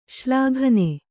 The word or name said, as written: ಶ್ಲಾಘನೆ